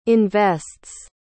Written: invests

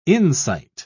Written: insight

